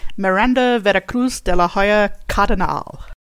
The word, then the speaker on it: Miranda Veracruz de la Hoya Cardinal: Miranda Veracruz de la Hoya Cardinal.